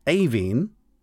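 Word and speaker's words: Aoibhinn: which is A-O-I-B-H as well, but it ends with E-A-N-N. And the first one of those is pronounced Avine, so you were close.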